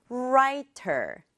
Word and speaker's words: writer: 세 번째 단어는 두 박자예요. writer이죠. 앞부분에 강조 줘서 writer. 부드럽게 발음하면 writer.